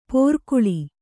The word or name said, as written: ಪೋರ್ಕುಳಿ